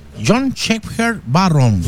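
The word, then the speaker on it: John Shepherd-Barron: John Shepherd. Lo pronuncié bien, ¿o no? Sí, John Shepherd Barron.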